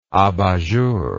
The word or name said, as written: abat jour